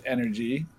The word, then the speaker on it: NRG: It's pronounced Energy, E-N-E-R-G-Y, even though it's NRG. – Yeah. – And the caster and everyone says NRG.